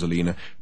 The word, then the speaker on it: Alina: It was about a week ago, when Dirk had tried a simple subterfuge to trick Alina — the old bat's name was Alina, pronounced to rhyme with cleaner, which was an irony that Dirk now no longer relished — into opening the fridge door.